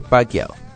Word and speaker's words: Pacquiao: Siempre de la Hoya peleó con jugadores de primer orden, como Félix Trinidad, Shane Mosley, Floyd Mayweather, y además de Pacquiao.